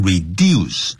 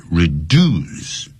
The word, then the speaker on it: reduce: 尽管如此，他这并没有减少这个 lesson，在里面就得 reduce, R-E-D-U-C-E，不要念成 reduce。在 in American English, that word is pronounced As, 怎么样呢，发音成所谓的 reduce, 不是 reduce。